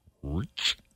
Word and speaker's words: Ricch: Rockstar by DaBaby and Roddy Rick. Is it R-I-C-C-H? How do you pronounce that? Rick or Rich? Rich.